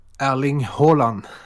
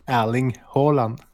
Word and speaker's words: Haaland: Erling Haaland. – So not Haaland or Haaland, like I heard people say.